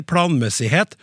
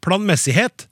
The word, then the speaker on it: planmessighet: Jeg har lagt merke til at Harald Eia i programmet Sånn er du på P2 konsekvent uttaler planmessighet som planmessighet, med trykk på mess.